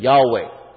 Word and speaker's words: Yahweh: 在希伯来文里面，主的发音就是 Yahweh. – And in Hebrew, the pronunciation for Lord is Yahweh.